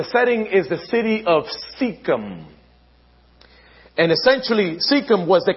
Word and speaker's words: Shechem: It's pronounced Shechem.